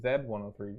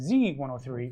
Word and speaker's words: Z 103: Zed 103 Vito. – Z 103. – We are Canada. Yeah, which is a Canadian show, but it's pronounced as Z 103 because it sounds better.